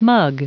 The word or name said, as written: mug